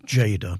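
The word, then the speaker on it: Tjader: It's pronounced Jada, but it's spelt with a T, T-J-A-D-E-R, Cal Jada.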